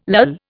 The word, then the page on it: lull: Indian